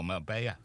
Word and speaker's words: Marbella: Echo special report reports that Spanish police thought wanted criminal Philip Foster was in Marbella, or Marbella, you pronounce it, don't you? That's how you pronounce it.